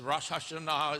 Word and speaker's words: Rosh Hashanah: And one of those feast days is called Rosh Hashanah, or you may hear it as Rosh Hashanah, but it's Rosh Hashanah is the correct pronunciation of it.